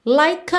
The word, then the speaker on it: like: Like, na verdade, termina em E, mas esse E não é pronunciado. Então, em termos de som, essa palavra termina no K, você já liga no A, ok?